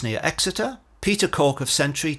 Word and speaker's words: Centree: This week, the Green Edge talked to a new company called Sentry, that's spelled C-E-N-T-R-E-E, which is part of this new blockchain-enabled ecosystem.